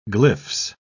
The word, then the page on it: glyphs: British English